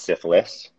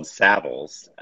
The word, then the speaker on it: Savills: It's not pronounced, and pardon if anybody is offended, it's not pronounced syphilis. That is a sexually transmitted disease. It's called Savills.